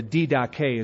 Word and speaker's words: Didache: A book surface called the Didache, or the Didache is the Greek pronunciation, the teaching of the 12 apostles on how to tell a true prophet from a false prophet.